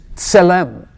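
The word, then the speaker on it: Tselem (צלם): T-S. Tselem. You have to press your tongue against your teeth. Tselem. Tselem. So T-S is a letter. And that letter in the Hebrew language is pronounced ts.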